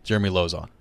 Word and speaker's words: Jeremy Lauzon: Jeremy Lauzon.